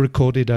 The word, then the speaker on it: record: So there you are, another one, changing the stress on the first syllable or the second. You change between to record, which is a verb, no? Grabar. And a record, which is una grabación o un disco.